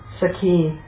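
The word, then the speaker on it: sakhī: Sakhi. Long E. With a short E, it's masculine. Friend, companion. With a long E, it's a female friend.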